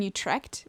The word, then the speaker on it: Utrecht: They recently had a Grand Prix in Utrecht where it was team draft.